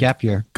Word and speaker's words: GPYR: But the last album, complete new materials, 2025's, is it just pronounced Gap Year? G-P-Y-R? – Yep. – Okay. – Yep, Gap Year.